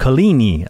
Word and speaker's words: Coligny: There was a leader among the Protestants who was named Coligny, and I'll spell that for you, C-O-L-I-G-N-Y, and it's pronounced Coligny, I think.